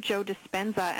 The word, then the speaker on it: Joe Dispenza: We are talking with Dr. Joe Dispenza.